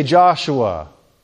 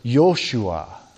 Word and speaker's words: Joshua: So we say Joshua, but the name was Yoshua.